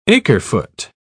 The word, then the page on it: acre-foot: British English